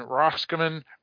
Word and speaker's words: Roscommon: I don't know how to say it, but Kevin said it. Roscommon. – I just see Roscommon. – Yeah. So I was pronouncing it based off of its spelling rather than where you're supposed to put the emphasis and stuff, which I do with a lot of words, unfortunately.